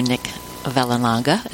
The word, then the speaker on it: Vallelonga: Now, I I, I met uh, screenwriter and producer Nick Vallelonga.